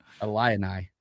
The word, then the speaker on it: Illini: How do you say it? Illini? There we go. I got it. I always struggle with that. Illini.